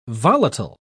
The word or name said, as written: volatile